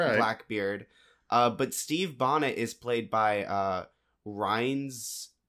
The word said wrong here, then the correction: Rhys: Darby, I believe that's how his name's pronounced. – Rhys Darby? – Rhys Darby? I guess R-H-Y-S? – It's spelled very weird.